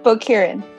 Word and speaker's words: Booqurien: Booqurien.